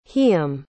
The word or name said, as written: hiyam